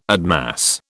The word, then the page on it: admass: British English